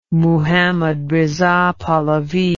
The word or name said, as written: Muhammad Riza Pahlavi